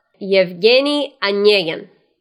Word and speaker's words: Evgeny Onegin: The Russian classic, and I'm going to use the Russian pronunciation of the book. Evgeny Onegin.